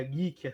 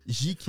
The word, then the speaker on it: geek: Do, da mesma área, geek, assim, nerd. – Geek, fraco né? Demais, você, você errou, você errou, errou a pronúncia, é geek.